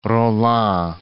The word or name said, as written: Rolland